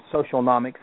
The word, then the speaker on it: Socialnomics: Eric Qualman, who is a phenomenal, wrote Social Economics or Socialnomics, that's how you say it.